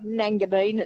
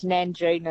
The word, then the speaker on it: Nangeenan: And good on you and your brother, the Nanganine Town Hall. – So, no, it's not Nanganine, it's Nanjean, and it's like N-A-N-G-E-E-N-A-N.